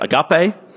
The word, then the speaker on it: agape: The word translated here as love throughout this passage is a word pronounced agape.